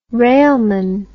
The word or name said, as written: railman